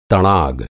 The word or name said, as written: तड़ाग